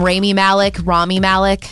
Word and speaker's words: Rami Malek: How do you say this actor's name? Rami Malek? That's right. So he's finally speaking out against director Bryan Singer, who faces more sexual abuse allegations.